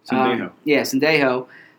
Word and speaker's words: Sendejo: Sendejo.